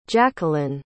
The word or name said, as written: jacqueline